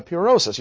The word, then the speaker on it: purosis: P-U-R-O-S-I-S. Pronounce again in English, purosis.